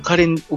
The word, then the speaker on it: 花蓮: はい、まあ、皆さんもご存知のように、 4 月の 3日にですね、台湾東部花蓮っていう町があるんですけども、はい、蓮の花と書いて花蓮ですね、でこの花蓮沖の、